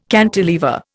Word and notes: cantilever: Indian

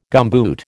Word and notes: gumboot: American English

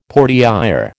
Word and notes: portière: American English

